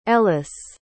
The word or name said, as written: Ellyce